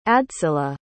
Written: adsila

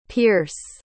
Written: peirce

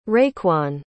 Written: raekwon

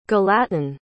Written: galatyn

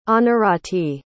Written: anurati

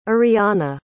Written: iriana

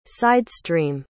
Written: sidestream

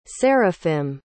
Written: seraphim

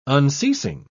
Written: unceasing

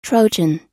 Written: trojan

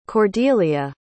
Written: Cordelia

